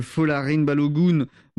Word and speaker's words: Folarin Balogun: Donald Trump qui appelle la FIFA pour lever un carton rouge qui visait le meilleur buteur de l'équipe américaine, Folarin Balogun.